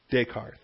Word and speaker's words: Descartes: Descartes, thank you.